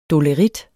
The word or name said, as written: dolerit